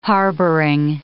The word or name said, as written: harboring